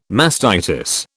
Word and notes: mastitis: British English

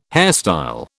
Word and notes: hairstyle: British English

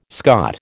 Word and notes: Scot: American English

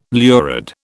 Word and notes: lurid: British English

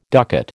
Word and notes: ducat: American English

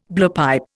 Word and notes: blowpipe: Indian

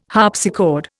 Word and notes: harpsichord: Indian

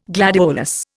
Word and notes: gladiolus: Indian

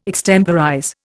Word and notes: extemporize: Indian

